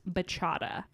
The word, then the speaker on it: bachata: So on the TikTok episode, I talked about this Latin dance and I called it the bachata, but it's actually pronounced bachata. – Ooh, like horchata. – Right. With a B.